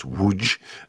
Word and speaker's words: Łódź: And I play a character who was a survivor of Auschwitz, who was born in Poland, lived in Łódź, which is how we pronounce that city that is spelled LLODZ, but it's actually pronounced Łódź, and was a very big Jewish center.